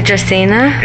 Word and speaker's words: dracaena: It's a dracaena. – It's there you have it, right there. See? Multiple syllables, too. It's a typical house plant. These are philodendrons and dracaenas.